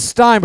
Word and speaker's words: Stine: Yeah, it's Stein, but it's spelled S-T-I-N-E, so it's like Palestine.